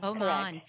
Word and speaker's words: Oman: Is it Oman or it is Oman. Oman.